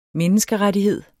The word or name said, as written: menneskerettighed